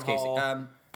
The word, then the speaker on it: Kasich: Like, that was John, that's Kasich.